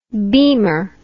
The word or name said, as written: beemer